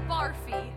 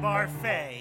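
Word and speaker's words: Barfée: Returning after last season's tragic setback, William Barfy. – It's pronounced Barfay. There's an accent ague.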